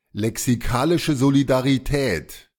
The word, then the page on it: lexikalische Solidarität: German